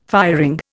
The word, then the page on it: firing: Indian